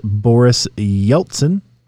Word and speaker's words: Yeltsin: In 1997, President Boris Yeltsin, I'm not sure I'm pronouncing that correctly. – That was right.